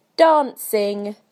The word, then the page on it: dancing: British English